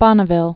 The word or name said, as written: Bonneville Salt Flats